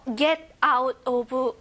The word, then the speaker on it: get out of: 자, 요거 get out of 발음하실 때요.